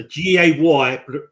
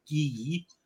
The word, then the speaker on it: Gaye: and he actually said his name is Gay so G A Y pronunciation. So you're going to need to go back to infant school, all right? The phonetics are G A Y E, all right? Gay, not Gee.